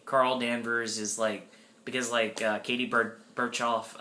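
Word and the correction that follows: Sackhoff: how do you pronounce Sarkoff.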